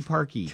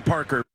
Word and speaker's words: Parkey: His last name rhymes with his first name. – Coder? Parker. – Cody Parkey. – Cody Parker.